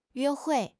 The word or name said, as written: yue1 hui4